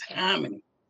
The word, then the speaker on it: Hominy: Say it right. See, you should have just gave me the cue and I would have said it's Hominy.